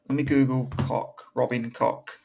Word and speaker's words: Koch: No, it's Koch. K-O-C-H.